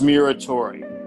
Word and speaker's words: Muratore: Yeah, my last name is Muratore.